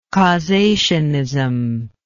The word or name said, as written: causationist